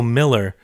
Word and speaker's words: Mueller: Oh, you can say his name right, even though it's spelled. Actually, it's Bill Miller.